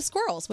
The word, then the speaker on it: squirrel: So he said his dad's got this thing going for the squirrels. – Which I'm sorry, which animal does Prince Charles love, Scary? – Squirrels. – Squirrels. – Squirrels. – It's the squirrel. – There you go. – Two syllables. Squirrel.